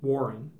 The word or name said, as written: Warren